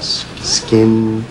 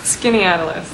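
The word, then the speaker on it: Skaneateles: What is it? Skin? – No one can. Skinny Atlas. – That's the one.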